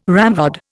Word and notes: ramrod: Indian

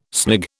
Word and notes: snig: British English

